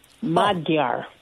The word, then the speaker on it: Magyar: So the Magyar, the G-Y is pronounced D-Y-E, like dia. Magyar.